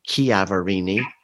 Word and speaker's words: Chiaverini: I've always heard it Chiaverini, but when I caught a podcast by her, she pronounces it Chiaverini, and the number is DB109619.